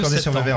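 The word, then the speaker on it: Corsier-sur-Vevey: Le 12 septembre, est-ce que je prononce bien, vous serez à Corsières-sur-Vevey. – Corsières-sur-Vevey. – C'est juste Corsières-sur-Vevey en Suisse.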